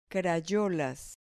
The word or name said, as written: crayolas